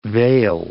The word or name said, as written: vail